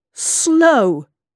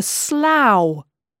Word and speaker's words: Slough: Then you won't say Slough or Slow for Slough or Green Witch for Greenwich.